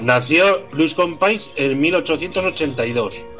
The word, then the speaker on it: Companys: Se pronuncia Companys.